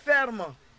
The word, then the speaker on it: FERM: Vous avez des idées F-E-R-M. – On le prononce ferme.